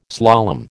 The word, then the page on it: slalom: American English